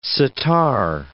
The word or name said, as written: sitar